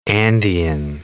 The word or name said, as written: Andean condor